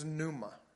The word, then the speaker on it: pneuma: And the Greek word for spirit is pneuma. Pneuma. It's spelled P-N-E-U-M-A. P-N-E-U-M-A.